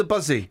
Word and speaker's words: Buszy: How do I say this? – The Buzzy. – The Buzzy. – Eh? – The Buzzy. – The group helped... It's spelled B-U-S-Z-Y. – Because it used to be a bus station.